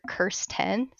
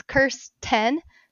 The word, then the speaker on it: Kirsten: It's under Curse10. So a curse times 10. That's kind of how I tell people how mm-hmm. my name's pronounced, because there's different versions like Kirsten, Kristen.